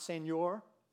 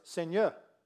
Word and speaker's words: Seigneur: It's not Seigneur, because I studied Spanish. It's not Seigneur, it's Seigneur. Louez le Seigneur.